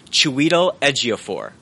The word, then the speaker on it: Chiwetel Ejiofor: Uh, it's it's actually it's pronounced Chiwetel Ejiofor from uh, Twelve Years a Slave.